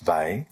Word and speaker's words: Weil: She was her surname is spelled W-E-I-L, but yep. pronounced Vey. – Vey, thank you.